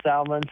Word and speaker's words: Salmans: My last name is spelled S-A-L-M-A-N-S. Or, as everybody on social media, the @ symbol, Chris Salmons Sun Life, they can find us there as well.